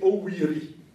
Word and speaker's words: Oviri: Oviri. Ovipi. Ovipi. – C'est Oviri. – Ça doit même se prononcer Owiri.